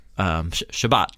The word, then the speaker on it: Shabbat: It was, yeah, it was the afternoon before Shabbat, is how you say it in Hebrew.